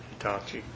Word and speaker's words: Hitachi: Motion for approval of Potter as the low bidder on the thumb for the Hitachi, is that the way you pronounce it? – Hitachi excavator.